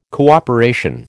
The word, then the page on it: cooperation: American English